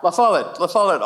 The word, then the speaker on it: La Follette: Say it correctly. La Follette. I'll get it right.